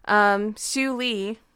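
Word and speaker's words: Šiauliai: And, history fact, in 1795, Siouxliai, was in... – Spell it. – C-I-A-U-L-I-A-I. – Sure, that sounds right.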